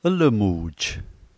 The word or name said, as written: lmuj